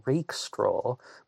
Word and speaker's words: Rackstraw: And I have heard at least one person online pronounce his name Robert Rakestraw, but everyone else has been going with Rackstraw, so I'm going to go with that one as well.